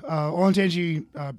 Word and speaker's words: Olentangy: But yeah, Olentangy Brewing Company.